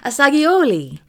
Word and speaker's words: Assagioli: So again, as we get to the end of this episode, I want to hit you with Roberto Assagioli. – Assagioli.